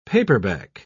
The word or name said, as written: paperback